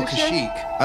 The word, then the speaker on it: Kashyyyk: Kesha? – No, Kashyyyk. I always pronounce Kashyyyk. Someone's going to call in and correct me on it, but Kashyyyk is the way I heard it.